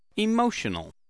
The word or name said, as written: emotional